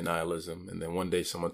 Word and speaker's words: nihilism: I wanted to make sure I get it right, and I went to, to search it on Google, did a pronunciation, and it is nihilism.